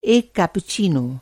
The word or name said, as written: कैप्पुचीनो